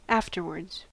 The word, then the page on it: afterwards: American English